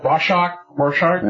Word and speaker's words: Rorschach: Rorschach, I can't even pronounce that, Rorschach? – Yeah. – Rorschach psychological profile on me, because it's kind of like, this for me right now, this is me.